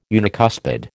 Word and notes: unicuspid: American English

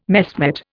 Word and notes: messmate: Indian

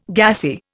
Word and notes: gassy: Indian

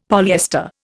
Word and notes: polyester: Indian

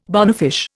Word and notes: bonefish: Indian

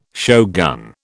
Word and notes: shogun: British English

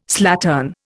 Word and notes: slattern: Indian

